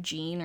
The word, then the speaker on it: Jeanne: It's spelled J-E-A-N-N-E, so you would think like Jean or Jeannie, but yeah. From the 911 call, when I heard her pronounce her own name, she pronounced it Jean Myers.